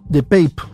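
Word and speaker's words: DePape: El agresor, identificado como David Pipe, de Pape, se debe pronunciar, de 42, años, irrumpió la vivienda al grito de ¿Dónde está Nancy?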